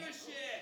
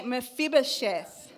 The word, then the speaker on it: Mephibosheth: Mephibosheth. – Everybody say Mephibosheth.